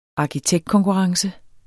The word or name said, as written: ARKITEKTKONKURRENCEN